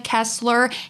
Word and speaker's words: Kesler: So go to YouTube and search my name, Hannah Kessler.